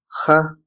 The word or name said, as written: ja